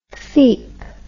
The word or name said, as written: feep